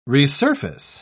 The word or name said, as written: resurface